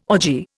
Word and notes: orgy: Indian

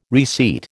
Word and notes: reseat: American English

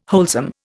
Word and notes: wholesome: Indian

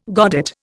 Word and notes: godet: Indian

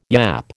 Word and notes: yap: American English

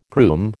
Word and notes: groom: American English

